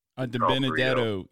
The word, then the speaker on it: DiBenedetto: DiBenedetto.